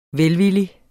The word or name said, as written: VELVILLIGT